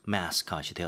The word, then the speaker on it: mascot: 많은 팬들이 지금 이 친구를 승리의 뭔가 상징이다 이렇게 보고 있는 그런 Merlin이요 has become the World Cup's unofficial mascot이 되었다라고 얘기를 하네요. – Okay, well, I think Korean people also say 마스코트. – 마스코트. – Yeah, yes. so in English we say mascot. – 이거는 좀 발음만 조금 바꿔주면 되겠네요. – Yeah, yeah. It's spelled M-A-S-C-O-T.